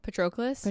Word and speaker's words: Patroclus: Patroclus. Patroclus is like has sex with her because he feels bad for her.